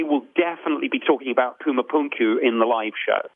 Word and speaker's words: Pumapunku: But for me, the one that sort of turned the corner for me is Pumapunku.